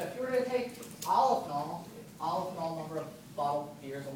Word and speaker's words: Aleph null: So it's pronounced Aleph null or Aleph not.